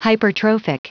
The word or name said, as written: hypertrophic